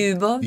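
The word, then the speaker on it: Ljubov: Du, din rolle i Kirseberghaven eh, Jobov, er det riktig uttalt? Jobov, ja. Kan du fortelle litt? Jubov, Jubov, Jubov, ja. ja. Det er de russiske navnene.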